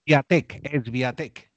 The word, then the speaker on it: Swiatek: Es, es Viatec, dígale así. Es Viatec, ok, la W suena como una V para nosotros. Eh, IGA y eh, Viatec.